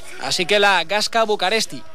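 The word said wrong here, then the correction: București: Gasca Bucuresti. Bucuresti. Bucuresti, efectivamente. Gasca, que es peña en rumano, y Bucuresti, que es como se pronuncia Bucarest en rumano.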